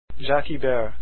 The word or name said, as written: ibert